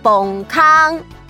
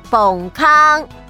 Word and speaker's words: 山洞: 山洞，台语念作崩坑，崩坑。